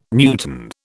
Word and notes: newton: British English

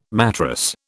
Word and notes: matrass: British English

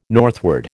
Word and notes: northward: American English

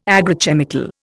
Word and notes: agrochemical: Indian

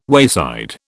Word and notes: wayside: British English

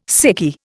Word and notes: sickie: Indian

